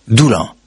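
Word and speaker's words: Doullens: Alors c'est Doulans, ça s'écrit, mais on prononce, paraît-il, là-bas. – Doulans.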